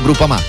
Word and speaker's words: Groupama: Groupama, si pronuncia Groupama, di Paolo Felicetti.